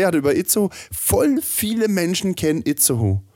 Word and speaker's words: Itzehoe: Spricht man das nicht eigentlich nur Itzehoe? Itz vielleicht, Oder ja genau, Itzehoe.